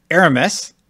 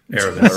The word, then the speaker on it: Aramis: On today's show, we're going to talk about, we got the story trailer with Aramis. Did I say it right, Brian? – You said it right. – Aramis.